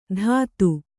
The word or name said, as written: ತತ್ತು